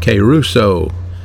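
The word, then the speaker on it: kairouso (κηρύσσω): That word preaching is the word kairouso, K-A-Y-R-O-O-S-S-O, pronounced kairouso, which means a herald, someone who heralds the good news.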